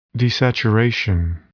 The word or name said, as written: desaturation